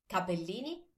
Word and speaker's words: capellini: Notice that it's capellini with one P and not cappellini, because capellini actually means thin hair.